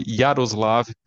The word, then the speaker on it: Jaroslav: Você tem, por exemplo, o Justo Gonzalez, que é católico, escreveu obras monumentais sobre a história do cristianismo, e tem também o Yaroslav Pelikan, que foi traduzido no Brasil, se escreve Jaroslav Pelican.